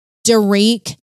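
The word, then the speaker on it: Derick: Now, I think it's Derek. – I think so too, yeah, because it's a woman. – It's spelled like Derek. – It only has one R, so it's D-E-R-I-C-K, so it's Derek Brown.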